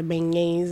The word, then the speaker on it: beignets: And I never got to get the beignets. It's okay. – Oh, yeah. – Camille brought me back some. Am I saying it right? Because I just be saying it. – Yeah, beignets.